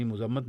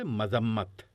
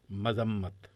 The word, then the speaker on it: مذمت: مذمت کے بارے میں عرض ہے کہ یہ غلط ہے تلفظ، میم پر پیش نہیں ہوتی، یعنی مذمت نہیں کہیں گے، زبر کے ساتھ اس کو بولیں گے، درست تلفظ ہے مذمت، مذمت۔ اچھا تھوڑا سا وقت بچا ہے تو ایک خط اور پڑھ لیا جائے؟